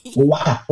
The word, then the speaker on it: Awak: Owak. What is it? – How you say it? – Owak. Owak. Owak.